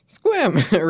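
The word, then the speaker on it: Sequim: A quick correction, Squim is pronounced Squim, rhymes with swim, rather than either of the attempts Annie made in the episode.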